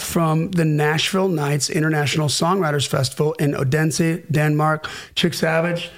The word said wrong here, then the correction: Odense: That's how they say it. Unsa. – Oh, they say Unsa. – There's a very soft D and they go, Unsa. – Unsa. Unsa, Denmark.